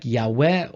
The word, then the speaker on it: Yahweh: Uh, it's something like Yahweh or Yahweh if you want to use the v, the vav instead of the the w with wow.